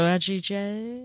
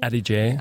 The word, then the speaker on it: Adige: And it's Alto Adige? – Adige. – Adige.